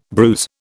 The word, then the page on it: bruise: British English